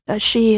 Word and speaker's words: sidhe: It's spelled S-I-D-H-E. She is a Irish fairy or person of sort of imaginary creation.